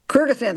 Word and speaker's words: Kyrgyzstan: embassies in Nicaragua, Grenada, Somalia, Uzbekistan, pardon me if I'm mispronouncing Kyrgyzstan. – It. Kyrgyzstan, thank you.